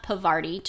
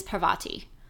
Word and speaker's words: Parvati: It is not Pavarti to rhyme with Havarti. It is Parvati. P-A-R-V-A-T-I.